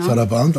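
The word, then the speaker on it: Sarabande: Saraband auf, mit der Betonung auf die zwei.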